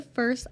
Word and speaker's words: FRST: So if you want to learn more about them, you can follow them at The First on Instagram.